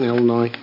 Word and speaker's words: Illinois: First of all, the S is silent in Illinois.